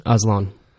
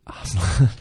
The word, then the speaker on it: Aslan: You know, we're going to talk about Reza Aslan. Is that how you pronounce his last name? – Aslan. – Aslan. Chronicles of Narnia.